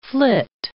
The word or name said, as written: flit